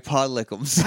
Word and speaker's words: podlickems: Hashtag pod lickums. – Hashtag pod lickums. And that's lickums with an E, not lickums with a U. – Let's spell it. – L-P-O-D, yeah. Hashtag P-O-D-L-I-C-K-E-M-S.